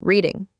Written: reading

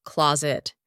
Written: closet

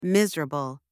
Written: miserable

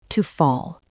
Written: to fall

